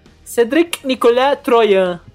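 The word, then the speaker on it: Cédric Nicolas-Troyan: Cedric Nicolas Troyan. – Parabéns pela pronúncia.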